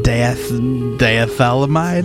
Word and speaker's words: diethylamide: Who first synthesized lysergic acid diethylamide, better known as LSD.